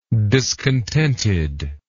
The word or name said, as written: discontentedness